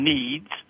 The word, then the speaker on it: NEADS: Now, it's spelled N-E-A-D-S, NEEDS.